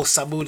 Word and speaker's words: Thamud: We're going to look at the people of Thamud right now, or Samud as people pronounce it. The Prophet alayhi salatu wasalam was sent, the Prophet Saleh alayhi salam was sent to people of Thamud.